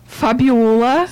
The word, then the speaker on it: Fabíola: Era para pronunciar meu nome certo, tá? – Tá, então, por favor, fale seu nome certo. – O meu nome certo é Fabiola.